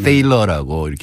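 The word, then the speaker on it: Thaler: 어, 먼저 THALER인데 우리나라에서 이렇게 번역이 나올 때 탈러라고 한 데도 있고 네. 테일러라고 이렇게 네. 발음한 것도 있는데 이분이 독일 출신이에요. 그러니까 음. 아마 집안에서는 탈러라고 부를 것 같아요. 탈러.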